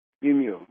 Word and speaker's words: Mimieux: Mimieux.